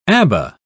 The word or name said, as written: Abba